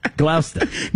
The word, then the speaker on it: Gloucester: There's Worcester, and then there's G-L-O-U... C-E-S-T-E-R. How do you pronounce that? – No idea. I don't even know what you just You're supposed to say Gloucester, then I go, no. Gloucester. Gloucester. – No. – No, it's Gloucester. And then one more, Berlin. – Okay. – I shouldn't say it out loud. B-E-R-L-I-N.